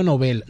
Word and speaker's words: Nobel: Me dice que la pronunciación adecuada es Nobel. Premio Nobel, no premio Nobel. Me dice que a pesar de que la utilización llana de Nobel está muy extendida, no es la correcta.